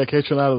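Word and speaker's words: Kaytranada: I also, you know, tell me if I'm saying this right, Kay Trinata? – Yeah, Kay Trinata was phenomenal.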